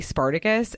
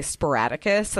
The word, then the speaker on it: Spartacus: But another notes that I had were, I've never seen the movie Spartacus, and I still can only say Sporadicus. Like, that is how I pronounce that movie.